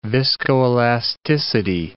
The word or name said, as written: viscoelasticity